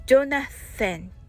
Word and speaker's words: Jonathan: ちなみに、ジョナサンのスペルは J O N A T H A N となりまして、えー、発音はジョナセンとなります、えー、ジョナセンのニックネームはネイセンまたはジョニーです、えー、ジョナセンのや、